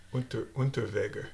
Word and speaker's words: Unterweger: Serial killers, Underweger? – Unterweger. – There, that sounds better. – If we want to pronounce it in the German way.